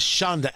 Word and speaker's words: shonda: That is shonda.